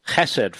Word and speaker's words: Chesed: And I want to remind you that you can go to the Chesed Fund. I'm told that's how to pronounce it.